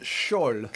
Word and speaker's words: Skjold: Now, my still using is the Skjold... Is that how you pronounce it? – Skjold. – Skjold. – I like the Norwegian accent.